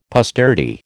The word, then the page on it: posterity: American English